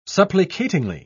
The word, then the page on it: supplicatingly: British English